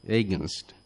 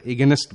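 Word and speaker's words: against: এরপরে আমরা যে শব্দটি ব্যবহার করব সেটি হলো এগেনস্ট, এগেনস্টের বিরুদ্ধে বা বিপরীতে, এগেনস্ট, আমরা এগেনস্ট বলবো না, আমরা উচ্চারণটা হবে এগেনস্ট, এগেনস্ট।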